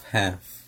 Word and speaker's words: half: We have half. – We have half. – La letra L en la palabra half no se pronuncia.